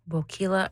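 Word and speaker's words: Boquila: Bokila rhymes with tequila.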